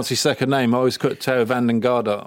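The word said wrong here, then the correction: Geoghegan Hart: Gegenhardt. – Gegenhardt. – Yeah, there you go.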